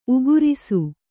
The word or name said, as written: ಊಗುರಿಸು